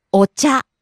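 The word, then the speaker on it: お茶: Ocha.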